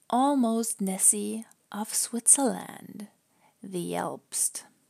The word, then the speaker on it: Elbst: It's a water spirit that lives in a mountain lake in Switzerland, and the name ELBST sounds a little bit like Alb again. And remember, that was the first syllable of the German word for nightmare.